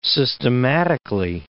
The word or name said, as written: systematically